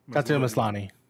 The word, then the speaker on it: Tatiana Maslany: Tatiana Maslany.